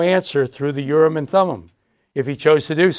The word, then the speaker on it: Urim and Thummim: properly pronounced Urim and Thummim. I am is always Im in Hebrew.